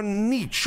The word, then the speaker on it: niche: That's your niche or niche. How do you say it? Niche. – Niche!